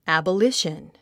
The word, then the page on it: abolition: æ`bəlíʃən; アボリション